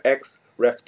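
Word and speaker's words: Xrd: Guilty Gear X Ref 2. x x ¿qué se pronuncia? Es, es XR.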